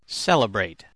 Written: celebrate

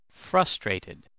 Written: frustrated

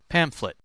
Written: pamphlet

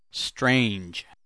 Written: strange

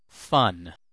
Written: fun

